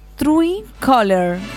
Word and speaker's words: Truecaller: Obtener True Color. Se dice color, ¿ya? Pero se escribe Coyer para que lo busquen. Trui, tal cual. T-R-U-E-C-A-L-L-E-R.